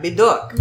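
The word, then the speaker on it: Médoc: Medoc. – So it's pretty close on that one. – Yeah, Medoc. – Medoc. – That's it. Yeah. And we were doing the H.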